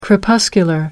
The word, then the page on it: crepuscular: American English